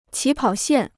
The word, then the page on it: 起跑线: qǐ pǎo xiàn